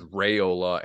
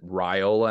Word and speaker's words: Raiola: I definitely thought it was Raiola, and then I heard his dad on the Georgia Rivals site pronounce it Raiola, and I was like, all right, so we're defaulting to that now.